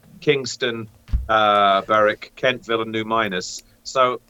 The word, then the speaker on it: Berwick: Can I call it Berwick? Because it should be called Berwick. – Yeah, you got to say it properly. Berwick.